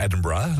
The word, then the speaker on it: Edinburgh: Zo moet je het ook uitspreken, Edinburgh.